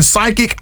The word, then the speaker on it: psychic: So you're right, because when we see psychic, it's spelled P-S-Y, right? – I couldn't tell you. – Yes, yeah. This, yep. – So the P is silent.